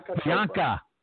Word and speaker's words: Priyanka: Pareka? – Priyanka Chopra.